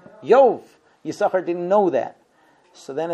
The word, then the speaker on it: Yisachar: We have two sins, and we pronounce it Yisachar without the second sin.